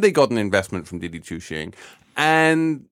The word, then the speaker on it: Didi Chuxing: I mean, Uber sold off to I can't pronounce Didi Chuxing. – Didi Chuxing. Did I actually get the pronunciation? – Pretty much.